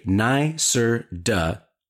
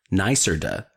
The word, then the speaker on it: NYSERDA: Sun Common is a nicer duh, N Y S E R D A, pronounced nicer duh gold quality solar installer, which means they exceed annual quality assurance criteria and consistently provide top tier performance.